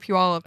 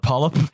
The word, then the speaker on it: Puyallup: I only just in the last like I would say 2 years nailed the pronunciation of it. – Yeah, I used to call it Polyp. – Yeah, I had an ex who used to call it Pullywop.